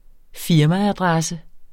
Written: firmaadresse

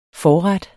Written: forret

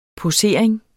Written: posering

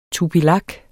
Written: tupilak

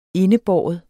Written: indebære

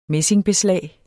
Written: messingbeslag

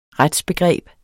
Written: retsbegreb

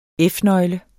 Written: F-nøgle

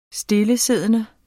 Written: stillesiddende

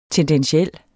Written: TENDENTIEL

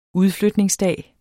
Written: udflytningsdag

